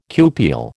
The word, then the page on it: cupule: American English